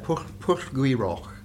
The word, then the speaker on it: Pwll Gwy-Rhoc: Now, you might be able to correct me, I might have been saying it wrong all this time. – Well, it's not bad. Pwll Gwyroc.